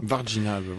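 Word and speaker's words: Varginha: Vargina, voilà, t'as peut-être trouvé la façon dont on le prononce.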